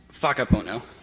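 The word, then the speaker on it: whakapono: The WF that you, or WH there that you see is actually pronounced with an F. So waka pono becomes faka pono.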